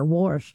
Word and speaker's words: Warsh: I didn't say enough R there, Warsh.